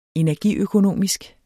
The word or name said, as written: energiøkonomisk